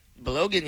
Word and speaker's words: Balogun: Falarin Balogun. Did I say that right? – Balogun, yeah.